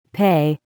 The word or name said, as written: pay